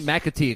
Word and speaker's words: McAtee: And then as of the shooting last night of Mr. David McAtee, right? That's how you say his name. McAtee, excuse me.